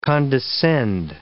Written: condescend

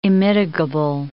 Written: immitigable

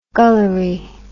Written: gullery

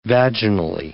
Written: vaginally

